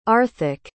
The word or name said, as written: arthek